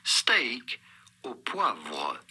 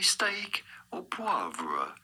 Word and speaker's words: steak au poivre: We are looking at how to pronounce with a typical French pronunciation, steak au poivre.